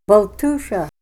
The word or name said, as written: baltusha